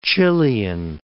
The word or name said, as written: Chilean